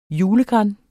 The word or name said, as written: julegran